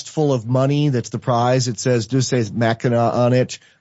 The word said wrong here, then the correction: Deus Ex Machina: How do you say that, DJ? Deus Ex Machina, which is Latin for the god in the basket, which is basically a cheat in the hero's journey.